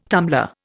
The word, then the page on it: tumbler: Indian